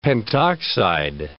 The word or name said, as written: pentoxide